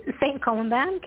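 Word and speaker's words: Saint-Colomban: She is in St. Colomban? Is that right? How do you pronounce it? – St. Colomban, Quebec. – Colomban, I was right, in Quebec.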